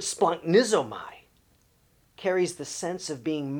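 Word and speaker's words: splunknizomai (σπλαγχνίζομαι): And that Greek word can be pronounced splunknizomai. Splunknizomai.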